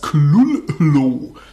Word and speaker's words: Cthulhu: The first syllable is pronounced gutturally and very thickly. Und er möchte also noch nicht mal das Schriftbild haben, sondern ein Wort, das steht hier als Klullo.